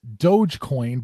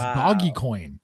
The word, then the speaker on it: Dogecoin: You're correct. It is pronounced Dogecoin, but I mean, it's confusing to me because their logo has a dog on it. So you would think it's Doggy Coin.